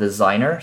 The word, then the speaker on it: Designrr: You can use a tool called Designer. So this is spelled design and two R's. At the end, there's no E. So Designer or Designer, we'll just pronounce it that way, Designer.